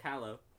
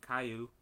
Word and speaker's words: Callow: Spanish side wants to pronounce the double L's a different way. – Caillou.